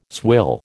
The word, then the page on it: swill: American English